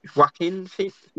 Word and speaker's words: Joaquin Phoenix: So, kept that streak of the fact that both him, Jonah Phoenix, and I probably butchered his name just then, and I think it's Joaquin Phoenix. – Yeah, Joaquin.